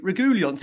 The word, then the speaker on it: Reguilón: Now, Andrew was on our show, was from Argentina, and he says to me, like, you've got to pronounce this guy's name right. – And I've got Mickey there saying, so I've always said Reguilón, right? And he's going to meet Reguilón.